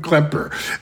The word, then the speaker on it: Klepper: And my guest co-host today is Jordan Klepper.